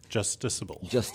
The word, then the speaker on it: justiciable: So the court said the state's map is not, I hope I pronounced this correctly, judiciable. – Justiciable. – Okay. – There's justice.